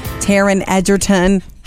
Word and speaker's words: Egerton: He was involved with Taron Egerton. Ha! Said it right. – Yay! – Got his name right. – Edgerton.